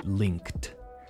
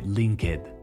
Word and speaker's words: linked: Ojo a esa pronunciación de linked. Nada de linked. It's a KT sound, linked.